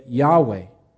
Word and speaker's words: Yahweh: So they wrote it YHWH. But in order to pronounce it, we have introduced vowels and we've added an A and an E and we get Yahweh.